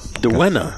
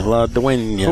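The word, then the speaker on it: La Dueña: It's a La Duena. – La what? – Duena. – How do you spell it? – D-U-E-N with the little tilde. – Oh, yeah. – Duena. – Duena. La Duena.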